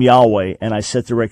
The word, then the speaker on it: Yahweh: But that just means the original pronunciation most likely would have been Yahweh, which is what most scholars believe and what I personally believe.